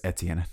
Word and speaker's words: Etienne: Derek Etienne Jr. It is Etienne, not Etienne. Please get it correctly.